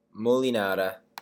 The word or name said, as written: molinara